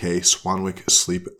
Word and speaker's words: Swanwick: That is, of course, Swanwick Sleep, I should say, if you want the better pronunciation for all you Americans, you gringos, S-W-A-N-W-I-C-K, Swanwick Sleep.